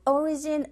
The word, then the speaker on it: origin: The origin of potatoes. Origin, 앞에 강세 주시면서 발음하면 되겠죠?